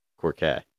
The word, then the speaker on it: croquet: Play croquet? – Quartet? Croquet. I know there's a T in there, but I think it's silent.